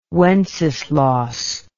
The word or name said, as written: Wenceslaus